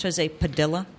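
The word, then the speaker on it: José Padilla: And his name is pronounced Jose Padilla? – That's what his mother tells me.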